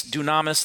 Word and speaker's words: dunamis: Now, if you check the root of that word, it comes from the Greek, which means dunamis, that's how you pronounce it, which means dynamite or miracle power, wonder-working power.